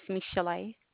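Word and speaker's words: Michel'le: My name is Michelle. – And how do you spell that, please? – That is M-I-C-H-E-L apostrophe L-E.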